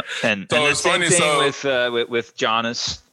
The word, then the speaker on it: Giannis: If you're gonna say the first name, it's Giannis. You say it properly with a G sound.